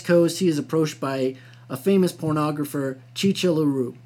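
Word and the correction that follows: Chi Chi LaRue: I think I pronounced that wrong. Shishi LaRue.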